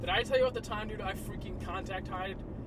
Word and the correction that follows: contact high: I got the kid... – Contact high? – Yeah, that wasn't the right way to pronounce it.